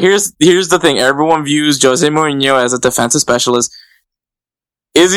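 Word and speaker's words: José Mourinho: I know a lot of people like to call him Jose Mourinho. It's Jose Mourinho. That's the proper pronunciation of Jose's name.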